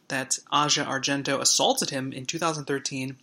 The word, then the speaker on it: Asia: Hashtag MeToo leaders are struggling to respond to the bombshell revelations that Asia Argento, is that how you pronounce her name? Asia? I've been saying Asia all week, and then I was corrected.